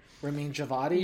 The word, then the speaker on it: Ramin Djawadi: Roman I never pronounce his name. The composer. – Ramin Djawadi.